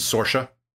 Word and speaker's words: Sorsha: And if you read it, if you had read it first and didn't know about that sort of pronunciation, I would not have pronounced that spelling. I would not have pronounced Sorsha, you know? So it's like, yeah, there's pronunciations like that all over the place that just don't quite fit English pronunciation guides.